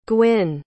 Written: Gwynn

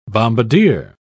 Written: bombardier

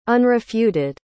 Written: unrefuted

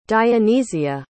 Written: dionysia

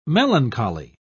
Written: melancholy

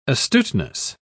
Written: astuteness

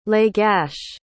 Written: Lagash

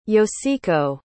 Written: yoshiko

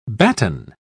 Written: batten

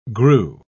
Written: grew

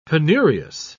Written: penurious

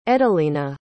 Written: edelina

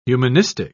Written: humanistic

